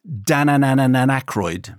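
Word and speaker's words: Dananananaykroyd: And I came across the other week, and I'm going to get this wrong, but the band are called Dananananan Aykroyd, which must be the most mispronounced name. So it's Dananan and then another Anan and then Aykroyd.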